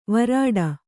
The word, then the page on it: ವ್ರೀಡೆ: vrīḍe